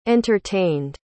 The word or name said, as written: entertained